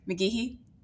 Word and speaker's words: McGehee: McGehee uh, High School. Did I say that right? McGehee? Yes, yes, yes, you yes.